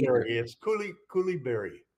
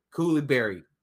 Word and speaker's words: Coulibaly: Coulibaly. – Coulibaly.